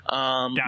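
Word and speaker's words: Dončić: And you've got Doncic. You've got, and I probably pronounced that really wrong, and I apologize. – Doncic.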